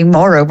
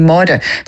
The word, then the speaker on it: Maura: And in fairness, because I'm from the Gaeltacht, my name is, and most people who say it, they do anglicise it, they say Maura, whereas really the right way to pronounce it would be Maura. Like, forget it, that's not going to happen. They're not going to get that R roll straight away, like.